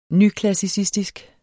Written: nyklassicistisk

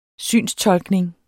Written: synstolkning

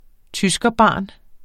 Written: tyskerbarn

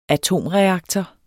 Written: atomreaktor

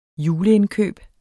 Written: juleindkøb

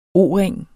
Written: O-ring

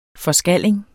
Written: forskalling

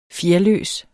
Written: fjerløs

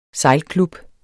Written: sejlklub

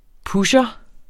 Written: -pusher

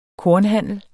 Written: kornhandler

